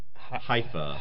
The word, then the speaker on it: Haifa: Haifa, as in the city of Haifa in southern Israel.